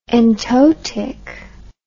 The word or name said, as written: entotic